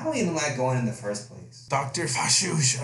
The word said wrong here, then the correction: Fauci: I fucking murdered this guy's name. How would you say that? Well, Dr. Fauci. Fauci.